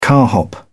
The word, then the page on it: carhop: British English